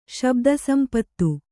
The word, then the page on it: ಶಬ್ದಸಂಪತ್ತು: śabda sampattu